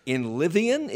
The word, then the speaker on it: Inlivian: To Inlivian? Is that how you pronounce it? – I believe it is. – I-N-L-I-V-I-A-N.